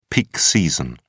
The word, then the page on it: peak season: British English